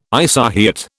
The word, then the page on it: isohyet: British English